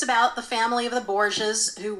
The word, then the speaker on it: Borgias: Borgias.